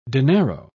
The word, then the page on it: dinero: British English